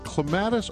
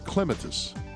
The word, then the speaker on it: clematis: Is it clematis or clematis? Because he was saying clematis. And his point was exactly what Gary and everybody else had just said. I don't care how you say it. Clematis, clematis, it's up to you as long as you plant them and enjoy them.